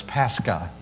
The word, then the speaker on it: Pascha: The Greek word for Passover is Pascha. You've probably heard that before. It's pronounced in Greek and Latin that way.